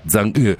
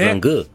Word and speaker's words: Chang'e: Attenzione, perché da qui a fine anno avremo le nuove missioni lunari cinesi, la Chang'e 7, mi hanno detto che si pronuncia Zhang'e 7. – Zhang'e.